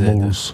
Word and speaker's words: Malz: Comment que ça s'écrit? – M-A-L-Z. – M-A-L-Z. – Moles.